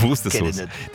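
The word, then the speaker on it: Worcestersoße: Wustersoße. Die schreibt man nur anders, nur dass du es weißt.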